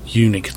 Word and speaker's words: Unic: Didn't last many years, and then the Unique taxi came around in about 1912. It's spelt UNIC.